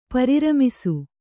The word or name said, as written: ಪರಿರಮಿಸು